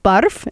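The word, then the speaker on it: barf (برف): And so I said, barf. Am I saying it right? Am I saying it correctly? And I have my four little students, and they're kind of giggling. And yes, yes. And one giggles more than the other. And really? Really? Barf? And they say, yes. Why? Why? You say it right. You say it right, miss.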